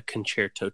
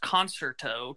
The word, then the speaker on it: concerto: Well, it's, a lot of people think that it's pronounced concerto because it's just concert with an O on it. – Yeah. – And that's not the way you say it. It's called a concerto.